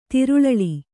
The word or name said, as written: ತಿರುಳಳಿ